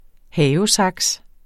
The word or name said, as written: havesaks